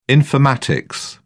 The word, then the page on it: informatics: British English